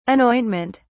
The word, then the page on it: anointment: British English